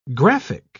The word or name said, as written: graphic